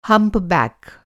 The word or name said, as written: हम्प्बक्क